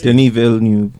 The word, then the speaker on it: Denis Villeneuve: I always say Denis Villeneuve.